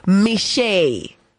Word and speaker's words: Michee: Michee, you know, we're speaking off air and I kept getting his name wrong because it's spelled M-I-C-H-E-E. So I was like, oh, Michee or Michee. And he's like, no, my name is Michee, but you must put the French accent. And if you don't have the French accent, then you cannot say my name correctly.